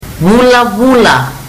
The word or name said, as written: vulavula